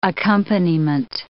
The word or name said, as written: accompaniment